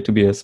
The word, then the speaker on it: Tobias: It would be Tobias, right? – Yeah, we would say Tobias, but. I'm very liberal regarding the pronunciation of my name.